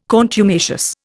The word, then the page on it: contumacious: Indian